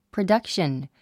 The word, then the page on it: production: prədʌ’kʃən; プロダクション